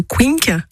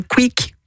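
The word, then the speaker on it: Quincke: Alors, j'ai souvent des patients moi qui me parlent et qui ont peur d'avoir le fameux œdème de Quink. Alors, ça se dit Quink du nom du médecin, même si souvent on entend quick, quink, on entend différents noms. – Voilà, la vraie prononciation, c'est œdème de quink.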